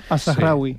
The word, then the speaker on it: Al-Zahrawi: Pues mira, el primero que he traído es Al-Zahrawi. Ya me dirá si lo he pronunciado bien o mal. – Al-Zahrawi.